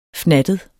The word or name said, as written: fnattet